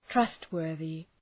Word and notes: trustworthy: ‘trʌst,wɜ:rðı

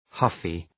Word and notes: huffy: ‘hʌfı

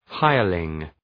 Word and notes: hireling: ‘haıərlıŋ

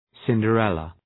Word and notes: Cinderella: ,sındə’relə

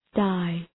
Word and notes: die: daı

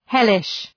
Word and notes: hellish: ‘helıʃ

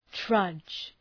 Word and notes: trudge: trʌdʒ

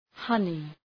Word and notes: honey: ‘hʌnı

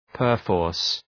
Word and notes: perforce: pər’fɔ:rs

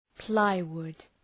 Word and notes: plywood: ‘plaı,wʋd

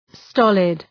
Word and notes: stolid: ‘stɒlıd

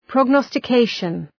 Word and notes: prognostication: prɒg,nɒstə’keıʃən

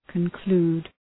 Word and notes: conclude: {kən’klu:d}

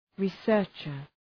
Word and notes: researcher: ri:’sɜ:rtʃər

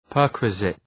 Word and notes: perquisite: ‘pɜ:rkwızıt

